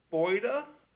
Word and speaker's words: Boyda: All right, so the name is Ruth Boyda. – Okay, please spell that last name. – B-O-Y-D-A. – B-O-Y-B-A? – D-A, yeah, as in dog.